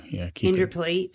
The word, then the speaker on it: Indooroopilly: Probably I would say Indooroopilly too. – Indooroopilly to Kenmore.